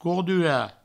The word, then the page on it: cordurar: courdura